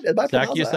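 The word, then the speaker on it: Zaccheaus: Probably Zacharias, right? Yes, Am I pronouncing that right? – Zacharias, the Olamide Zacharias.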